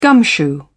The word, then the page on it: gumshoe: British English